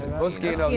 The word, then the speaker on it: Moschino: Last up, Cardi B in Machino. – So good. – I love it. – Did I pronounce that right? Is that Moschino.